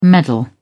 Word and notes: meddle: British English

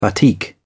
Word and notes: batik: British English

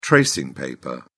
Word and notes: tracing paper: British English

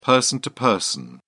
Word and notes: person-to-person: British English

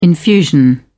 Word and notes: infusion: British English

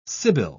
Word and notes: sybil: British English

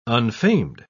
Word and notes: unfamed: British English

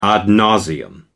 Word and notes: ad nauseam: American English

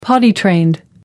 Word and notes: potty-trained: American English

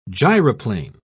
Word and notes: gyroplane: British English

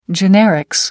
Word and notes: generics: American English

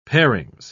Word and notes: parings: British English

